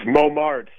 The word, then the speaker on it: Momertz: It looks like a Dutch name. You say Momertz or Momertz? – It's Momertz. Yeah, it's Dutch and Hungarian.